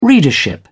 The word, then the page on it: readership: British English